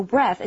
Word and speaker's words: breath: Now, notice the pronunciation of these words is a little bit different, too. The TH for breathe is like th, but for breath, it's a quiet one, right?